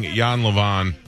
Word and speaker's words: Jan Lewan: The Polka King, Jan Levon.